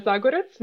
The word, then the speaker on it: Zagorac: My name is Luna Zagorac.